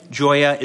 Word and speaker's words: Gioia: By the way, Gioia is pronounced Gioia.